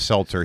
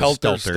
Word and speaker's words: Stelter: Well, Stelter, don't say it wrong. He's not a Seltzer. He's a Stelter.